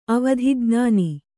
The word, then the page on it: ಅವಧಿಜ್ಞಾನ: avadhijñāna